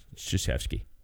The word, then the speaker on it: Krzyzewski: Your Izzo, Krzyzewski's, mm-hmm. all of those. – Coach K. – Yeah, Krzyzewski.